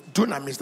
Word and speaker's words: dunamis: Acts chapter 1 verse 8 says, you shall receive dunamis. That is the Greek word is dynamis. That is they pronounce it dynamis, but it's dunamis that we call it, and that's the spelling.